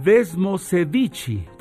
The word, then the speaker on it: Desmosedici: su Desmodici. Así se dice en italiano, perdonen ustedes por nuestra pronunciación, Desmosedici